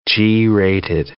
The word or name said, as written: G-rated